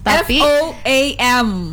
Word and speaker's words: foam: F O A M. – Foam. – So the correct way to pronounce is foam.